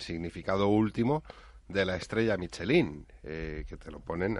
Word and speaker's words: Michelin: André y Edouard Michelin. – Muy bien. – Michelin.